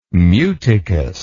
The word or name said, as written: muticous